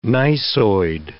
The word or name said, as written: gneissoid